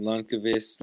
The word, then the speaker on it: Lundqvist: I personally like pronouncing his last name Lundqvist like that. I think that's the correct way to say it.